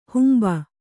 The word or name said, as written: ಹಂಬೆ